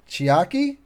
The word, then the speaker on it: Chiaki: Chiaki? – Yep.